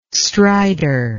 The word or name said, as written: stridor